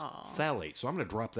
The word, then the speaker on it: phthalate: And they last about 4 weeks, and they're phthalate free. I'm going to nail that one because I actually, it starts with a PHTH, but it's phthalate.